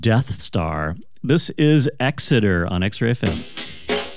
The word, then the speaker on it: Exiter: And we're going to start this one off with a band called Exeter. I'm pretty sure that's how it's pronounced, E-X-I-T-E-R, not Exciter.